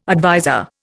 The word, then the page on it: adviser: Indian